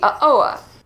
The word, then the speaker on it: Uh-Oa: So Trader Sam's has this drink called the Uh-Oa, spelled like uh-oh.